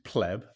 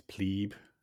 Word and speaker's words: pleb: which is pleb as we say it like english people um, but americans kind of think it's plebe because i think that's how they say it at school